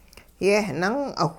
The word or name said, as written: yehnung'awh